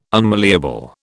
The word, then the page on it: unmalleable: British English